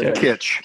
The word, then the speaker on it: Kitsch: and it's with Michael Shannon and Taylor Klitsch, and John Leguizamo is in. Did I pronounce his name wrong? I'm not sure. – Kitsch. Yeah. – Thank you very much. – Definitely not Taylor Klitsch.